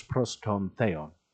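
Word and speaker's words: proston theon (πρὸς τὸν θεόν): If there's so much to explore from enarche enologos kai logos proston theos kai theos enologos. Excuse me, I said that wrong. You'll have to pardon my very, very rusty Koine Greek. Let's try that again.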